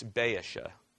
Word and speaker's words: Baasha: If you want to pronounce that the Hebrew way, it's Baasha.